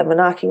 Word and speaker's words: manaaki: the manaaki was amazing. Did I say that right? Yeah,